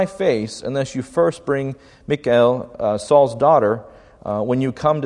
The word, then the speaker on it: Michal: It's Michal, basically, is how it's pronounced.